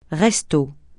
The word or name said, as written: resto